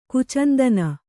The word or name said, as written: ಕುಚಂದನ